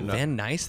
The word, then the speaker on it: Van Nuys: Van Nuys? That's how you say that? – Yeah. – I've been saying Van Nuys, like, for always. Nuys. – Nuys.